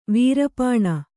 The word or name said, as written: ವೀರಪಣ